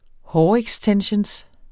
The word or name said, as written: hårextensions